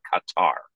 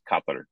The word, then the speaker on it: Qatar: When Avner says Qatar, this is the Arabic way to say the nation that most English speakers say Qatar. So Qatar is the name of the country, but again, the Arabic way to say it is Qatar, and Avner's simply pronouncing it correctly.